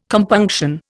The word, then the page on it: compunction: Indian